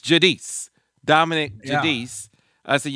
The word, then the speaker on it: Giudice: One of them, and we finally got his name right, Dominick Giudice. Giudice. Giudice. – Giudice.